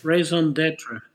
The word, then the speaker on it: raison d'être: Yes, raison d'être.